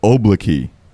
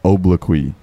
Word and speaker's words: obloquy: obloquy.